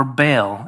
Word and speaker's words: Baal: Baal, or Baal, as you might pronounce it.